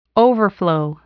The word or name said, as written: overflow